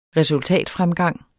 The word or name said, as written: resultatfremgang